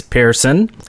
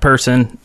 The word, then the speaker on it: Pearson: And in the news today, Marcus Pearson, and that is how you say his name, by the way. It's Pearson, not person, not parson, not whatever.